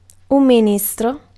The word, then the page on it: ministre: France; [mi.nistʁ]